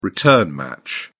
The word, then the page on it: return match: British English